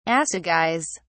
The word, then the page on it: assegais: British English